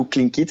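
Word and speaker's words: Tlingit: Et, et, Yakutat, notamment, c'est les kits donc ça, ça s'écrit pas du tout Clinkit, ça s'écrit T-L-I-N.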